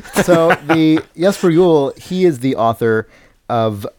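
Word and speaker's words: Jesper Juul: it's pronounced Jesper Jewel. As if the J's were wise."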